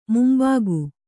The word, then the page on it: ಮುಂಬಕ್ಕಿ: mumbakki